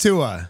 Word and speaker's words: Tua: It's Tua.